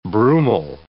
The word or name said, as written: brumal